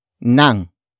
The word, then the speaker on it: ng: The answer is introduced by the complement phrase, starting with nang. Nang. Nang. Nang is spelled as NG.